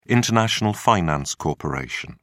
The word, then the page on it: international finance corporation: British English